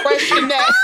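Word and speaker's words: Freixenet: Girl, first of all, that's pronounced fresh in that.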